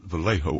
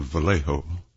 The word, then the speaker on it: Vallejo: And he paused, and he said, I'll tell you, here in California, it's called Vallejo. We pronounce the J's as H's. It's called Vallejo.